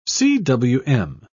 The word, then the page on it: cwm: British English